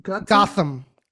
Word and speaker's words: Gotham: Gotham.